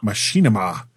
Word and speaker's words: Machinima: And there's this thing, and I know I'm going to say this wrong, it's Machinima. Which is Machinima.